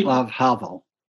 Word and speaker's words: Václav Havel: Václav Havel. – Václav Havel. – Václav Havel.